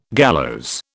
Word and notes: gallows: British English

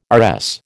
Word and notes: RS: American English

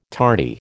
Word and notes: tarty: American English

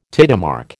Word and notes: tidemark: American English

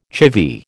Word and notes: chivvy: American English

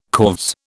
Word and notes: corves: British English

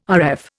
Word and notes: Rf: Indian

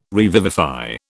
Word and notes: revivify: British English

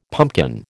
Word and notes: pumpkin: American English

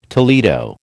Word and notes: Toledo: American English

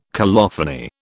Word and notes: colophony: British English

